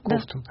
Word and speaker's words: Ковтун: Меньшов, Воронов, Ковтун. Правильно я ударение делаю? – Да, Ковтун. – Да.